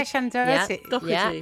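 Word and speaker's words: Shannen Doherty: as well as a Shannon Doherty. I think I said that correctly. – Yeah, Shannon Doherty.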